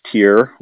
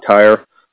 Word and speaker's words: Tyre: So uh, let's take a second example of prophecy, the prophecy of Tyr or Tyre, however you want to pronounce it. I think most scholars say Tyre. I say Tyr a lot.